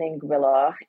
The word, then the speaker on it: Anguilla: So, I was born and raised in Anguilla, and thank you for pronouncing it correctly.